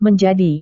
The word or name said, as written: menjadi